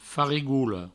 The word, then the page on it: farigola: Provençal; farigoulo